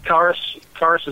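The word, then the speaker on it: Kharis: And I mean, there were plans we've always heard, like the Mummy Karras. Am I pronouncing that right, guys? – I think so. – Karras is good.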